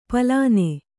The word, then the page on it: ಪಲಾನೆ: palāne